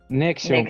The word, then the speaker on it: NXIVM: реально Nexium. тяжко. Nexium.